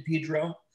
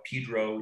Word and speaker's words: Pedro: He had lived in Pedro, yet they call it Pedro, even though it's spelled Pedro. Took me a long time to get that right.